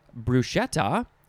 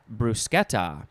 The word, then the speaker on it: bruschetta: So this book says it can be pronounced bruschetta or bruschetta. But I think there are other ways that people like to say it. It is spelled B-R-U-S-C-H-E-T-T-A, bruschetta.